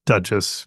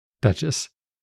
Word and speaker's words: Duchess: I think you would pronounce this the Duchess. I don't know. Duchess S? I'm not sure. They're basic, yeah. Right? – Yeah. Just Duchess. Yeah. – Okay. Duchess.